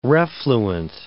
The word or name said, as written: refluent